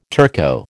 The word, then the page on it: Turco: American English